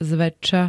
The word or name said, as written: zväčša